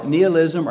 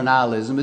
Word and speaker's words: nihilism: You can call it nihilism or nihilism. It's pronounced both ways.